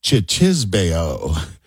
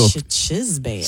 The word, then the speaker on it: cicisbeo: It's spelled C-I-C-I-S-B-E-O. It's pronounced Chichisbeo. So Chichisbeo.